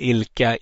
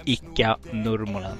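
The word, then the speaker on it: Ilkka Nurmonen: Men det rätta är ju Icka Nurmonen. Så att ingenting annat. Vi pudlar, vi ber om ursäkt. Eh, men vi kan också säga att vi vet att det finns andra poddar som säger Ilka etc. etc. Men Icka Nurmonen är rätt eh, uttal.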